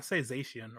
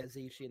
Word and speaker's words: Zacian: I say Zacian or Zacian. – Yeah, Zacian. That's normally what I go with.